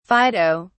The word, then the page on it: fido: British English